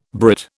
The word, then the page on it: Brit: British English